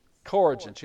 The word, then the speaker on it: coregents: Corrigence? Am I pronouncing that right? C-O-R-E-G-E-N-T-S. Hear that? Corrigence.